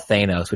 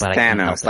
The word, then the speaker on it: Thanos: And it has a bad guy called Thanos, who I call Thanos, which I know is wrong. – Yeah, it's Thanos, like Van. – Right. It's a short, it's not a long A.